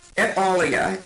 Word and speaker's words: et alia: Et alia is how it's pronounced in the Latin phrasing, and it means and others.